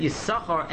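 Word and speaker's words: Yissachar: Although the Chassam Sofer says that the first time in the Torah that the name Yisachar is mentioned, you should pronounce it Yisachar. But typically, it is pronounced Yisachar.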